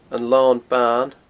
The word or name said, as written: anlonban